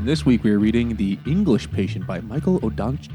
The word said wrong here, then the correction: Ondaatje: Ondaatje. I had to, I looked up how to say it. Ondaatje, yeah.